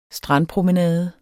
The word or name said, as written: strandpromenade